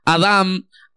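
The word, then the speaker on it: adam (אדם): Recuerden que la palabra Adán, diga conmigo Adán, en hebreo, en hebreo se pronuncia con M, no con N. adam Adán significa terráqueo, viene de una palabra, bueno, la palabra real es Adán, así, que se traduce como Adán.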